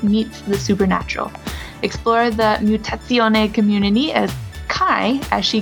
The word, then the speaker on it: Mutazione: This week we're talking about Mutazione, which the internet says is how you're supposed to pronounce this. It looks like Mutazione for you only English speakers like myself.